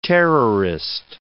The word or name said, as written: terrorist